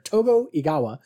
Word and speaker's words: Togo Igawa: He was played by Togo Igawa. Sorry, I tried to throw an extra syllable in there. Togo Igawa.